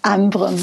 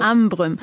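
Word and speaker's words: Ambrym: Und jetzt müssen Sie mir helfen, Abrüm? – Ambrüm, spricht man das aus. – Ambrüm.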